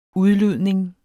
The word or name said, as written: udludning